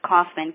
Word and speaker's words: Kaufman: It's Gwen, G-U-I-N-N, and then it's Kaufman, K-A-U-F-M-A-N.